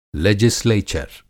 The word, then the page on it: लेजिस्लेचर: لیجسلیچر